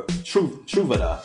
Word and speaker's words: Truvada: Now, even though we know PrEP, which is, I hope I'm pronouncing this correctly, pre-exposure prophylaxis, PrEP, or Truvada, I believe is how you pronounce it.